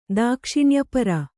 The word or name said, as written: ದಾಕ್ಷಿಣ್ಯಪರ